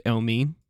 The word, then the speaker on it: Elmiene: Elmine, spelled E-L-M-I-E-N-E, but the project's called Elmine because it looks, that's how you say his name.